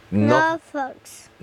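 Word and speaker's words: NOFX: Weißt du, wie die Band ausgesprochen wird? – No Fox. – No Fox, genau. No Fox. No FX.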